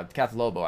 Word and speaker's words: Kath Lobo: I mean, Kath Hobo, I mean, I would, yeah, Kath Lobo actually is how you pronounce her name.